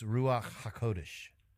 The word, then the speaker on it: Ruach HaKodesh: A more truer pronunciation of it is Ruach HaKodesh.